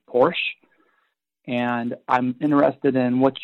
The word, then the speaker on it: Porsche: All right, this is Porsche, Porsche, if you want to say it correctly. Uh, I, I, I know that because I had one and I said Porsche and I was corrected many times.